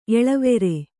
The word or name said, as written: ಎಳವೆರೆ